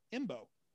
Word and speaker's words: Embo: Oh, Imbo, yeah. Good call. – His name's Imbo. – How you spell that? – E-M-B-O. Imbo.